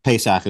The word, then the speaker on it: Pesach: Pesach is how it's said in Hebrew.